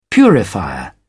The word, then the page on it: purifier: British English